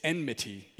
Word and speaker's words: enmity: I say enmity. It's enmity, E-N-M-I-T-Y.